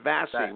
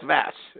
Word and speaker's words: Vasse: I've been saying Vassy with an emphasis on the E. – That's okay. – At the end. Yeah, but it's Vass. It's Vass. V-A-S-S-E.